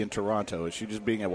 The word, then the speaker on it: Toronto: But Toronto, I've never heard.